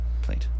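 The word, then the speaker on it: plait: Plate is how that's pronounced? – Plate? – Plate. – Yeah, yes. Yeah.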